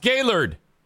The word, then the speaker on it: Gaylord: And it's pronounced Gaylord. It should be G-A-Y-L-U-R-D.